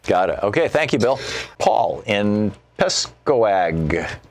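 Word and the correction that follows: Pascoag: Rhode Island. I'm mispronouncing it, aren't I, Paul? – Hey, Tom. It's Pasco, Rhode Island, but good okay.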